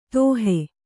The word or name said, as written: ದೋಹಿ